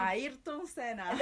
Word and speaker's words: Ayrton Senna: Ayrton Senna.